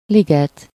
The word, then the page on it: bois: [ɛ̃ bwa]; /bwɑ/; /bwa/